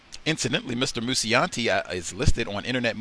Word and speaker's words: Mucciante: Now, I believe you pronounce his name Timothy Mucciante. M U C C E I, excuse me, M U C C I A N T E, Mucciante. I think that's how you say it, but he was the one.